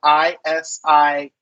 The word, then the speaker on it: Isiah: So I'm going to say it's spelled Isiah.